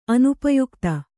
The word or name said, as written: ಅನುಪಯುಕ್ತ